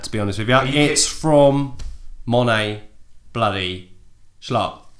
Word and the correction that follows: Scarp: Scarp. You got his name wrong, you idiot. – Monet Bloody Scarp. – I'm not editing that out. He's going to hear you fuck it up. – Monet Scarp. Did I get it right there? – Monet Scarp. Okay.